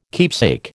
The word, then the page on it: keepsake: American English